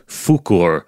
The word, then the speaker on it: Fuchur: And apparently it was changed in the English translation because the name Fuchur sounds too much like an English language swear word.